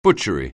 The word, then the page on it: butchery: anglais